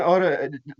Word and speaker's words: Ota: All right, John Oda.